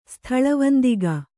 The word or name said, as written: ಸ್ಥಳವಂದಿಗ